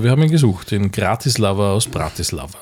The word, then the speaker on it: Bratislava: Und wir waren auch absolut auf Zuckerschock unterwegs, meine Damen und Herren, in Bratislava, wie ich sage, weil so ist eigentlich die Aussprache. Das heißt nicht Bratislava, sondern Bratislava. Und das muss ich ja blind glauben, weil ich habe davon noch nie gehört.